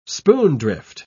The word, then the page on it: spoondrift: British English